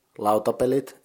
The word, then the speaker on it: lautapelit: To make it plural, you add t to the end, so it's lautapelit. Thus, the Finnish board game publisher, lautapelit, is pronounced lautapelit.